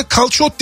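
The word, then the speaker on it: calçot: Ha, bu daha önce konuştuğumuzda kalsot dediğin şey bu değil mi? Yani yabani pırasa, Türkçesi yabani pırasa diyebileceğimiz şey aslında. – Evet, kalsot. Kalsot. Yani kalsot diye yazılıyor, kalsot diye okunuyor.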